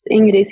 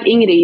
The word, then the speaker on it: Ingrid: They might want to pronounce the D at the end. – Yeah, people do that. But yeah, it's like Ingrid. You don't say the D.